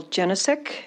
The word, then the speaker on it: Jenicek: My name is Michelle Jenisek. It's spelled with two L's. Last name is J-E-N-I-C-E-K.